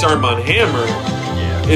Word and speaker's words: Armand Hammer: Armand Hammer. – Oh, okay, I totally mispronounced that. – Armand Hammer, like, you know, like.